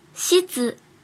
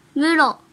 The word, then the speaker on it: 室: 和小一一起学日语第一百三十课，今日汉字，教室的室，音读的时候读作西子，西子，西子。比如教室，教室，教室，教室，写成日语汉字也是教室。训读的时候读作 muro。